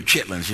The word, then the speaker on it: chitterlings: You'll hear chitlins, you know. But it's actually pronounced chitterlings, but black folks say chitlin.